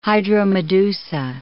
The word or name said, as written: hydromedusa